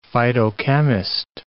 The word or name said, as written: phytochemist